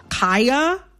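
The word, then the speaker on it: Khia: I am going to, in this conversation, pronounce Kaya, the rapper, with the long I.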